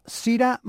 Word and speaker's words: Syrah: Para el final hemos dejado a Sira Morrison. Si lo buscáis por internet, Sira se escribe S-Y-R-A-H, Sira Morrison.